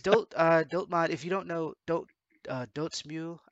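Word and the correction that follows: Dotemu: I can't say the name properly. – Dot emu. – That it's released dot email? – I'm pretty sure it's dot emu.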